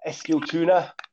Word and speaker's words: Eskilstuna: Eskilstuna, how's that going? Is that right? – That's perfectly fine.